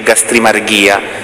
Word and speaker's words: gastrimargia: Si scrive gastrimargia ma si legge gastrimarghia, è quello che per i latini per il catalogo dei sette vizi capitali viene chiamato gola.